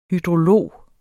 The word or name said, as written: hydrolog